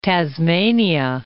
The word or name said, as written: Tasmania